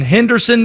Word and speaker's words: Henderson: Do the people in Henderson, North Carolina pronounce it that way? They do not. It is two syllables the way that people from Henderson pronounce it. Henderson.